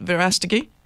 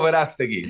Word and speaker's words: Verástegui: Did I pronounce that right, Verástegui? – Perfect. Okay. Better than I do. Eduardo Verástegui.